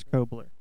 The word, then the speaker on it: Cobler: That's Chris, C-H-R-I-S. Last name is spelled C-O-B-L-E-R. So Chris Kobler.